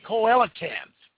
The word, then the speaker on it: coelacanth: It's called a coelacanth.